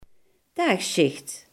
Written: dagdienst

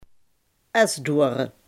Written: esdoorn

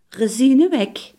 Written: rozijnenbrood

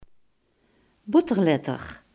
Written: banketstaaf

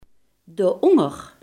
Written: daaronder

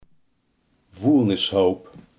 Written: vuilnisbelt

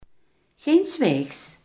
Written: geenszins

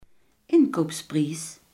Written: inkoopprijs

